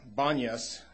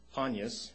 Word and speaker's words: Banyas: But some of them, Mr. Ashley told me that the Arabics don't pronounce P's in their alphabet, so they would be Banyas, even if it's pronounced Panyas.